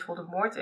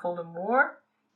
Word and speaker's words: Voldemort: En dan zegt hij het en het is Voldemort. Ja, Voldy. Voldy. Daarover ook heel even dat eh, JK blijkbaar in een of andere tweet een keer heeft uitgelegd dat het eigenlijk helemaal niet Voldemort is, maar Voldemort.